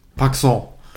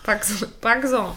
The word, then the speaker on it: pacsen: Ich weiß auch gar nicht, ob ich das richtig ausspreche mit dem, ob das Paxen überhaupt so. – Ja. – Aber ja. P-A-C-S-E-N. – Paxon. – Paxon. Genau.